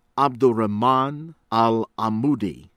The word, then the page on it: Abdurahman Alamoudi: from U.S.